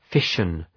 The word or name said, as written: fission